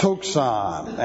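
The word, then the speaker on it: tuxon (τόξον): The accusative singular, direct object, from the noun tuxon, looks like this in the Greek, and that's T-O-X-Z-A-N, and it is pronounced tuxon, and it is correctly translated a bowl.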